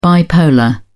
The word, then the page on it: bipolar: British English